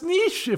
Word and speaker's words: Niš: I mean, I was trying to figure out the map as we were going along, and I, I thought, Niš that's Niš, if I'm pronouncing it correctly. – Yeah, that's, yeah, perfectly.